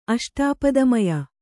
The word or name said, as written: ಅಷ್ಟಾಪದಮಯ